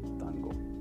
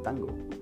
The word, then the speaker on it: tango: Afrodescendientes que utilizaban normalmente el tambor y que lo utilizan ahora obviamente en ese tiempo no le decían tambor, le decían tango, con el acento en la O, tango. Así es. Desde ahí nace la palabra tango. Ya después, de acuerdo a cómo iba evolucionando, se cambió el acento y ahora se dice tango, no tango.